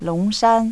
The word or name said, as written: longshan